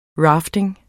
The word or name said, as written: rafting